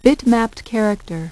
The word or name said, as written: bitmapped character